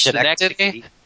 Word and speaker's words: Schenectady: I'm also the guy who knows how to pronounce the name of the movie, which is Schenectady.